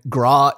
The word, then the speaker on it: Groch: It's spelled G R O C H, and I looked up pronunciation, and Graw is the best I'm going to do.